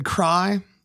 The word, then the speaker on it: Kraai: Again, my name's David Kraai. That's spelled K-R-A-A-I. Sounds like cry. It means crow in Dutch, like the bird.